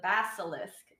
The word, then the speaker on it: basilisk: But the next cryptid I will be talking about is the basilisk. And I might say it wrong from now on, but you said it right the first time.